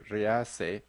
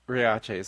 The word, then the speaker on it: Riace: For some reason, I was trying to pronounce the town Riace, the Italian town Riace, in the Spanish way, which would be Riace. Don't ask me why I got confused, but it's Riace.